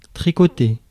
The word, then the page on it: tricoter: France; [tʁi.kɔ.te]